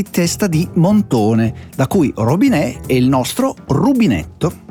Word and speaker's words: robinet: Piccolo Montone è il nome francese del rubinetto, o meglio, rubinetto deriva dal francese robinet. – Eh? – Vabbè, facciamolo pronunciare a chi lo sa pronunciare. – Robinet. – Più o meno l'ha detto come me.